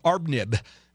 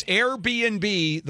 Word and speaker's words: Airbnb: All right, coming up, and we're going to finish it up with Airbnb, which I first pronounced Arbnib. It's Airbnb.